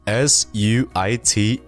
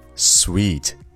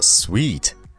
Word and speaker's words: suite: 好，下面再来说一下第二组同音异形词，s w e e t sweet or s u i t e sweet。那这两个词都读作 sweet，但是很多人会把 s u i t e 这个词读成 suit 或者是 suit。